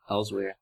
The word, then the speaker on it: Elsweyr: Is that how it's pronounced? – Yeah, I'm pretty sure. – Elsweyr.